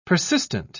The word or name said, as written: persistent